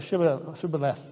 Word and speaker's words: shibboleth: And sort of a shibboleth, that's how you say it, of that we all have our little code here.